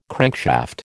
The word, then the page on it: crankshaft: American English